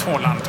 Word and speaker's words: Haaland: Erling bröt Haaland, som han ju heter ifall man ska uttala alla hans norska namn.